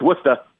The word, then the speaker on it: Worcester: And so is it Worcester? How do you say it in that particular part of the? – Yeah, I struggle with that. If you're from the east, it's Worcester.